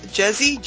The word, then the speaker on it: Jezzie: The character's name is Jezzy, J-E-Z-Z-I-E.